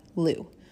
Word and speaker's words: Lugh: And as I said, this is also called Lunasa, because in some traditions, this day honors the Celtic god Lu, L-U-G-H.